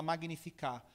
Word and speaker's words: Magnificat: Se escreve Magnificat, né? se fala Magnificar.